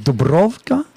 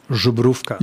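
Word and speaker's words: Żubrówka: Would you pronounce, is it Dubrovka? – Zubrovka. – Zubrovka.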